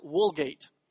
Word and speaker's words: Vulgate: My point is, though, that in Latin you pronounce the V's with a W. So back here to the Vulgate, that would really be pronounced Woolgate.